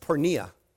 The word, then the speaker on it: porneia: Sexual immorality is, in the Bible, a Greek word that is pronounced porneia.